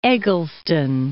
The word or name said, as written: Eggleston